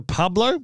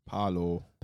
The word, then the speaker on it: Paolo: He's a Pablo? Paolo? – Paolo.